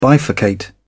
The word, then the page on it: bifurcate: British English